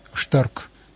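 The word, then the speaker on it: stark: Но есть еще слово «сильный» по-немецки, «штарк». Или пишется она «старк».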